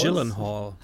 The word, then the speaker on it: Gyllenhaal: Gyllenhaal.